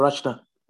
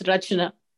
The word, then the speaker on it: Rachna: Sister Rachna. – Yes, I'm so sorry. Yes. Sister Rachna, why is it spelled Rikana? – The CH is spelled as sort of chair. It's not, it's Rachna.